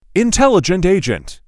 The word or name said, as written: Intelligent agent